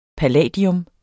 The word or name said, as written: palladium